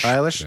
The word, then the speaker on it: Eilish: Eilish.